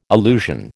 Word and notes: allusion: American English

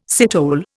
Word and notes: citole: Indian

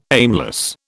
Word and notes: aimless: British English

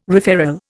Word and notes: referral: Indian

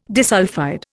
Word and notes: disulphide: Indian

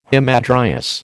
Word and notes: hamadryas: American English

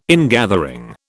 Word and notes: ingathering: British English

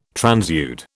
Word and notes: transude: British English

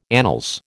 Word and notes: annals: American English

